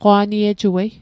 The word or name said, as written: qwa'niejuei